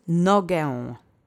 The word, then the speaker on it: nogę: And the last word is nogę. This means leg. This is a noun in its singular feminine object form. Let's say nogę together three times. Accent is on the first syllable.